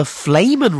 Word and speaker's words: Flehmen: But it's actually called the Flamin response. There's a scientific reason why they do this. And it's the Flamin response, spelled F-L-E-H-M-E-N.